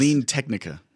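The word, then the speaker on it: CleanTechnica: Clean Techna. How do I can never pronounce it. – Clean Technica. – Thank you.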